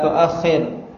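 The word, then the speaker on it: tu'akhir (تؤخر): La tu'akhar amal al-yawmi ilal ghadi. La tu'akhar. Berarti maknanya? Hah? La tu'akhir atau la tu'akhar? Hah? La tu'akhir amal al-yawmi ilal ghadi.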